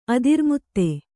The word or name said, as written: ಅದಿರ್ಮುತ್ತೆ